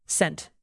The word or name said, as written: sent